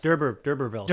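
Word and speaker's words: d'Urbervilles: Like the Dubervilles and all of that. – Durbervilles. – Durbervilles. Sorry.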